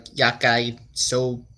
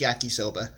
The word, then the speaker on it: yakisoba: Or if you're Google, it's pronounced like yakisobe, something like that. I'm pretty sure it's yakisoba.